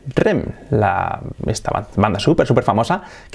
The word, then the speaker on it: R.E.M.: Bueno, aquí en este caso tiene truco porque se dice letra por letra. Se dice R-E-M. En inglés, R-E-M. R-E-M.